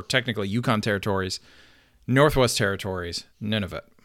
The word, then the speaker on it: Nunavut: Nunavut.